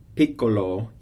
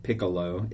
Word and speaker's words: piccolo: Piccolo, you have the double C. So once again, you dwell on that double consonant. It's not like piccolo, it's piccolo. So think of pronouncing it twice.